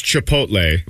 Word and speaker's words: Chipotle: Well, it's Chipotle.